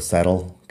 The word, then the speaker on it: settle: Cuando estoy hablando un poco más rápido, Ah, sale el acento americano y digo settle, que es una T más suave, como una D casi. en todo caso, las dos pronunciaciones valen, es simplemente cuestión de cuánto énfasis estoy poniendo en la palabra.